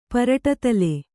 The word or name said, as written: ಪರಟತಲೆ